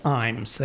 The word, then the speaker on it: Ijames: And the reason why I had to wait for my thing to come up is because his last name is spelled capital I-J-A-M-E-S. Mm-hmm. And it's pronounced Imes. I had to find some places that they said, it's like Times without the T.